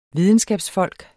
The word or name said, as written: videnskabsfolk